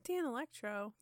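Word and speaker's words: Danelectro: So they got a Moss Wright guitar for Johnny and a Dan Electro bass for Dee Dee. – Dan Electro. – Yeah. No. I might be saying it wrong. But nope, that's right.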